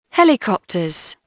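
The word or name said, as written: helicopter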